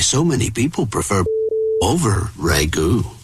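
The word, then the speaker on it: ragù: I think it's ragu. – It is pronounced ragu.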